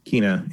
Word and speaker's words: Kena: Um, and then next up from the state of play, Kenna. Actually, we now know it's called. You pronounce it Kena yeah.